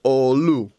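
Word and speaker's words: oğlu: Το οποίο στα τουρκικά διαβάζεται ολού.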